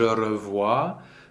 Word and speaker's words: revois: Je revois. Uh, the S is silent. Just in passing, the OI combination is wa in French.